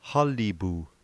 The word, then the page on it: qalipu: slow